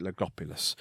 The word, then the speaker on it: Logopolis: And then obviously we have the Logopolis, that's how you say it, Logopolis.